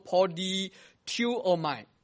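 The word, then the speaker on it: polytuomai (πολιτεύομαι): 这个字的读音读作 polytuomai 啊 polytuomai 在新约圣经的里面总共只有出现两次，一个就是腓立比书第一章 27 节，另外一个是使徒行传 23 章第一节，也是出自保罗的口中。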